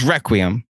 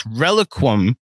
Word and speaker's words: Reliquum: Because John says Requiem. And that's not Requiem that is being spelled. It's Reliquum. Reliquum. There's 2 U's.